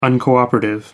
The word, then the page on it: uncooperative: American English